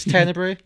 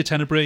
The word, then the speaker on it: Tenebrae: So Tenebrae, however we want to pronounce it. It's Tenebrae, yes? – I say Tenebrae, yeah.